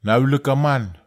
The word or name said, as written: nawelekaman